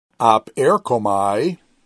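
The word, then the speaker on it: op erkomai (ἀπέρχομαι): So the op comes off as a syllable rather than the p going with the epsilon rho that follows it. The p does not go as a consonant, does not go with the epsilon rho that follows it because you split between words first before you do the syllable splitting. So it's op erkomai.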